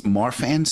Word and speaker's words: Marfan: It, it's uh, it's and it, forgive me if I'm mispronouncing it. It's Marfan syndrome. – Yes.